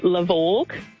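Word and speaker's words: Levorg: It's apparently a Subaru LaVorgue. – LaVorgue. Okay. – LaVorgue, yeah. – I look at that. – Cyborg, apparently. – Oh, yeah. That's grovel spelt backwards.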